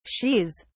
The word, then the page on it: shes: British English